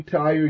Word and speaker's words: Tyre: Now, when I say Tyre, in your mind, you can spell it T-I-R-E, but it's spelled in the Bible T-Y-R-E.